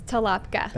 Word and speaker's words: Tolopka: Cassidy, how do you say your last name for everyone? – It's Tolopka.